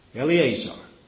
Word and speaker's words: Eliezer: how do you pronounce that? Eleazar. I always pronounce that wrong.